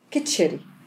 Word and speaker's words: كتشري: كتشري نطق كده كتشري، فحتى الاسم بالنسبة لي مرتبط طبعا بحاجة جاية برضو من بعيد، كتشري أو كشري يعني قريبين قوي من بعض.